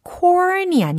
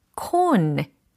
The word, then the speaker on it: cone: corn이 아니라 cone. 이렇게 발음을 하셔야 된다는 거예요. 왜냐면 철자가 C-O-N-E 이거든요. 원뿔에 해당하는 발음입니다. 만약에 corn이라고 하면 뭔가 곡식을 떠올리게 하잖아요.